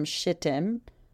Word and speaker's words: Shittim: Then Joshua, the son of Nun, secretly sent out two spies from Shittim. And this is spelled Shit and Tim. That's exactly how it's spelled.